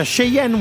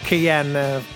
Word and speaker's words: Cheyenne: Mi sono sbagliato con la pronuncia, Cheyenne Woman, non Cheyenne. Avevo in mente la macchina io, vabbè.